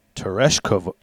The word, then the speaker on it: Tereshkova: Well, Valentina Tereshkova. How do you say it? – Tereshkova.